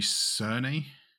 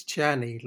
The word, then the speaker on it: Czerny: And that's Henry Czerny. – I think it's Czerny, like as in the Czech Republic.